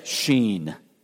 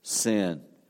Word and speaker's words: Sin: In fact, the Hebrew pronunciation would be more of Sheen, the wilderness of Sheen, not the wilderness of sin.